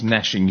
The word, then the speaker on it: gnashing: It's gnashing. You don't pronounce the G. It's just gnashing, not gnashing.